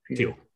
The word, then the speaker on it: Thiel: You know, he's, it's like Peter Thiel, or does he pronounce it Thiel? – Thiel, yeah, Peter Thiel.